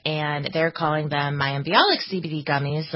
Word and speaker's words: Bialik: It's Bialik, right? Am I doing that correctly? – Yeah, you got the last name right, Mayim Bialik.